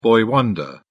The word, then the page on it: boy wonder: British English